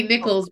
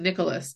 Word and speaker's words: Nicholas: I used to say Nichols, but it's actually Nicholas. It's Chani Nicholas.